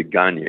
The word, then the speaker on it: Gagne: It's a French name. It's really Gagne is the way it's pronounced.